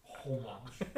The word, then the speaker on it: homage: Homage. Homage. How else could I say it? Homage. – Homage. – Homage. I want to pronounce the H. Let me have that. – Fine. Pronounce your damn H.